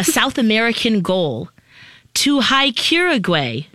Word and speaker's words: Uruguay: The poet camper, a South American goal, to hike Uruguay.